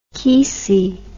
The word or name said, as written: kesey